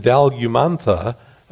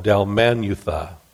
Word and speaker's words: Dalmanutha: Mark 8:10 tells us that they landed in Dalmanutha, I'm saying that wrong, Dalmanutha, which was a seaport near Magdala, which was just rediscovered in the last few years.